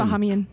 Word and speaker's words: Bahamian: You change the emphasis? – On the other syllable? – Hmm. Yeah. It's Bev? – Bahamian. – Bahamian. – It's Bahamian.